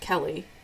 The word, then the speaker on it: Keli: Please share that with me on Instagram at I am Keli Jackson or find me on Facebook. My coaching page over there is just Keli, and my first name is spelled with four letters K E L I.